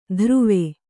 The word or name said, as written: ಧ್ರುವೆ